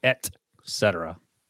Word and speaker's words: et cetera: How do you say the full version of ETC period comma? – Et cetera, yes. Not et cetera.